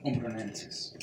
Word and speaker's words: Umbronensis: As you all know, we've got the excavations at the Roman Temple area of Diana in, I always pronounce that wrong, Umbronensis. – He says it much better than I do.